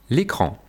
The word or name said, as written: L'écran